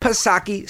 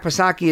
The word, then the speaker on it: Psaki: So, of course, You've got good old Jen Circleback Psaki, silent P, of course. Jen Circleback Psaki is at the White House press briefing on Monday saying there isn't a crime problem in the United States.